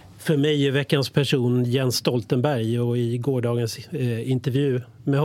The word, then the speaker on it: Jens Stoltenberg: Jens Stoltenberg, så uttalas han på norska.